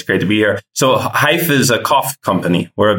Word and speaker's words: Hyfe: He is the co-founder and CEO of Hyph, as in like rhymes with life. It's spelled H-Y-F-E, and it's a smartphone app, and it tracks the sound and frequency of what?